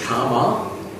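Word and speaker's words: kāma: And it is, the Pali word for it is kama. Would you write down? K-A-M-A. Okay, and you make a dash above the first A. So this means it's a long A. Not to be mistaken with kama.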